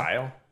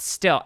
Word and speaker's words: Styll: So style? – Yeah. – S-T-Y-L-L? – No, it's pronounced still. S-T-Y-L-L. Yeah. – S-T-Y-L-L.